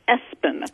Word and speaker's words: ESPN: It's pronounced Espen.